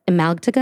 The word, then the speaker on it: amygdala: such as the amygdala.